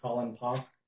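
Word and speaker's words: Poff: My name is Colin Pop.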